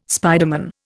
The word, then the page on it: spiderman: Indian